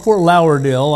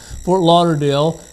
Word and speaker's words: Fort Lauderdale: Fort Lauderdale, Florida. I learned how to pronounce it. It's not Fort Lauderdale, I found out. Fort Lauderdale.